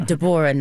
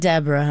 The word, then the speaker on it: Deborah: Yeah, Deborah, not Deborah, honey. Okay, this is Whitley Gilbert Wayne's child. She was not naming her no Deborah. Deborah, three syllables.